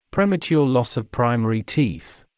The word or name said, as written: premature loss of primary teeth